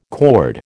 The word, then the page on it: cord: American English